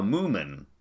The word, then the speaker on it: Moomin: But this is not a mispronunciation, because you gotta say the moo, like you're saying a cow, Moomin.